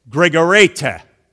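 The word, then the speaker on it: Gregorete (γρηγορεῖτε): So I'll say that word. You repeat it, cadets. Congregation, you too, okay? Gregorete. Gregorete. Gregorete.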